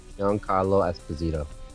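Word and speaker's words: Giancarlo Esposito: Giancarlo Esposito.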